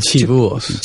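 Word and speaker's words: Chivuos: Chuvios.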